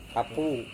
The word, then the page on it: kapu: Serewen